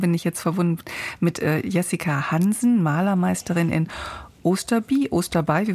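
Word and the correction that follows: Osterby: wie wird es ausgesprochen? In Schleswig-Holstein auf jeden Fall. Guten Morgen, Frau Hansen. – Guten Morgen, Osterbü ist richtig. – Osterbü, hallo.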